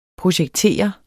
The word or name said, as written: projektere